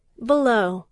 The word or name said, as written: below